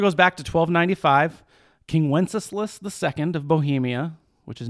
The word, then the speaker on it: Wenceslas: I'm still impressed as you said Wenceslas. Wenceslas.